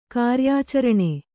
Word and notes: ಕಾರ್ಯಾಚರಣೆ: kāryācaraṇe

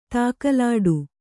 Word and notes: ಧಗಲಿಡು: dhagaliḍu

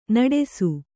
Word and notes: ನಡಸು: naḍasu

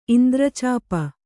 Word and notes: ಇಂದ್ರಚಾಪ: indracāpa

